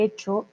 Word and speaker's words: hecho: No vamos a decir hecho con la H, recuerden que la H no se pronuncia.